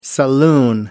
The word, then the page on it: saloon: American English